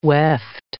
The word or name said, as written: weft